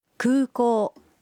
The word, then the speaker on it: 空港: Kūkō es aeropuerto. Los sonidos u y o en kūkō se pronuncian alargados.